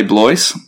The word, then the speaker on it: Blois: I say Blois because it's a bit easier for the normal non-fluent French-speaking person to say.